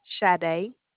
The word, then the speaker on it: Sade: Okay, my name is Sade, and it's spelled S-A-D-E. – Say that one more time. – Sade. – Spell it. – S-A-D-E.